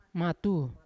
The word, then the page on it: matu: Yawa